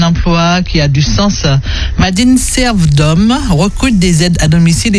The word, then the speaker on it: MADINSERVDOM: Alors, pour postuler, envoyez votre CV à Madine Serve d'Homme.